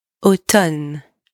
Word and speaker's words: automne: Then, do not pronounce the M in the word automne. It's not automne. It's automne. Pretend that the M is not even there.